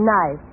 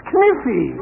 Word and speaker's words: knife: K-N-I-F-E. – K-N-I-F-E? That's Kniffy. – No, the word is knife. K is the silent letter. K is always silent in front of N.